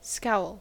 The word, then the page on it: scowl: American English